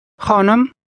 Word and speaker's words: خانم: خانم. خانم. نم. خا. خا. خانم. خانم. – Say miss or ma'am. – خانم.